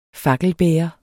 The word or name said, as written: fakkelbærer